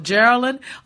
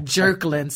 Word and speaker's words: Jearlyn: Jearlyn, oh my gosh, I've been called everything like Jerklyn, so there you go.